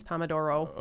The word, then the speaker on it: Pomodoro: Pomodoro. It ends in an O. I say Pomodoro, but I think it's Pomodoro.